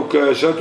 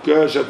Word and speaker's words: عكاشة: فقام عكاشة بن وعكاشة تنطق نطقين بالتخفيف والتثقيل، عكاشة وعكاشة. فقام عكاشة بن محصن فقال أنا منهم يا رسول الله؟